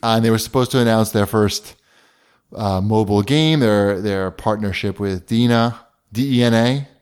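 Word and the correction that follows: DeNA: D-N-A. – D-N-A. Dana, I like to pronounce it. Ooh, there's something flying around here. – It's pronounced like DNA.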